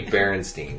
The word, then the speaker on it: Berenstain: It's got to be Berenstain because there has to be a bear at the beginning.